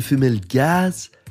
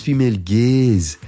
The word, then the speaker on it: female gaze: Je viens d'apprendre que le femelle gaz, ben, ça se prononce femelle gaze.